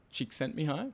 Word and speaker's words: Csikszentmihalyi: that was uh, provided by Mihaly uh, Csikszentmihalyi.